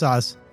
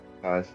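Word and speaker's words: Saaz: The hops is it Saz or Sav? – I pronounce Saz. – Saz, all right.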